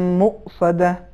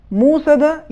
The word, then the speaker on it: مؤصدہ: اس سورے کی آخری آیت میں جو لفظ ہے مؤصدہ، اس میں حرف واو تلفظ نہیں ہوگا، بلکہ حرف واو کے اوپر جو حمزہ ہے یہ حمزہ تلفظ ہوگا، لہٰذا اس کا صحیح تلفظ یہ ہے علیہم نار مؤصدہ. موصدہ یہ صحیح نہیں ہے، چونکہ اس میں حمزے کی رعایت ضروری ہے.